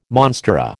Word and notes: monstera: American English

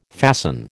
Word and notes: fasten: American English

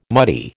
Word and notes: muddy: American English

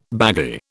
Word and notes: baggy: British English

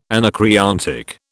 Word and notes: anacreontic: British English

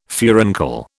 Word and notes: furuncle: British English

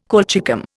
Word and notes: colchicum: Indian

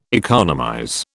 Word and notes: economize: British English

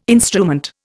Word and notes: instrument: Indian

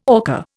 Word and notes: orca: Indian

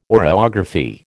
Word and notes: oreography: American English

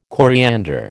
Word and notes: coriander: American English

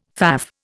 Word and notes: faff: Indian

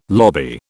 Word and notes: lobby: British English

